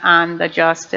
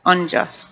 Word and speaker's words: unjust: I know I always say that wrong, unjust. It's not on the just, it's unjust.